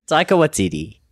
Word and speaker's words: Taika Waititi: That is like Tiger, but with a K, and then Waititi is like Waikiki. – Taika Waititi.